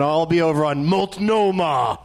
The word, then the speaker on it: Multnomah: Multnomah.